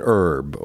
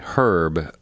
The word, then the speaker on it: herb: And so just to define our terms, what is the difference between a spice and an herb, or as you say in the UK, herb? We don't pronounce the H.